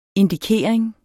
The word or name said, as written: indikering